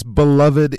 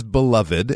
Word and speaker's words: beloved: When something is beloved, and we often do sort of stress that last syllable there, you could also say beloved, but often when we're using it to describe a person or thing that is much loved, we would say it in a slightly uh, longer way, beloved.